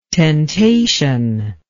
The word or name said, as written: tentation